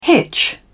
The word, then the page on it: hitch: British English